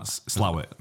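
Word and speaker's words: Slaithwaite: Slawit. – I'm just naming places now that I think are over there. – That's how it's pronounced though. Even though it's like written Slaythwaite, it's called Slawit.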